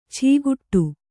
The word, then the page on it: ಛೀಗುಟ್ಟು: chīguṭṭu